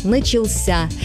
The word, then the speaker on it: начался: Но по нормам русского языка правильный только один – «начался» с ударением на последнем слоге.